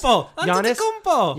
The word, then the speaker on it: Antetokounmpo: I got you. Giannis Antetokounmpo. No, I got this. Antetokounmpo. – Disney's Giannis Antetokounmpo. Antetokounmpo. – Antetokounmpo. – Yes. Antetokounmpo. Giannis Antetokounmpo. Had it, lost it.